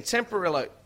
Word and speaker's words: Tempranillo: Tempranillo? Is that how you say it? – That's how you say it. – 2018. Tempranillo.